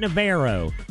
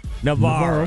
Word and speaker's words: Navarro: Okay, I want to make sure I say that right. Not Navarro. Navarro. – Navarro. – Okay. – You got it.